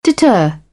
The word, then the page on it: deter: British English; ɜː